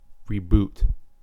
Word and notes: reboot: American English

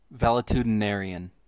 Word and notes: valetudinarian: American English

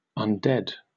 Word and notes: undead: British English

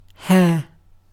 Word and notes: hair: British English

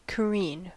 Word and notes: careen: American English

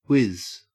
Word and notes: whiz: Australian English

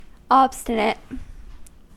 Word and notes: obstinate: American English

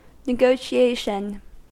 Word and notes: negotiation: American English

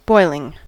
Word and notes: boiling: American English

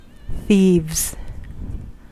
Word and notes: thieves: American English

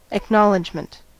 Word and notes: acknowledgement: American English

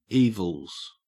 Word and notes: evils: Australian English

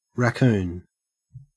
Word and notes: raccoon: Australian English

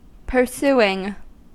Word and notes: pursuing: American English

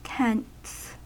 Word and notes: tents: American English

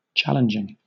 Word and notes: challenging: British English

